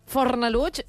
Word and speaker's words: Fornalutx: Te t'anava a dir, Fornalutx no deu ser fàcil de pronunciar ni pas castellà no parlants de la península, no? Fornalutx. – Deuen dir Fornalutx. – Perquè entre aquesta no, X final... – Sí, és veritat. Fornalux, diuen... Sí.